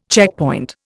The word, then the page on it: checkpoint: Indian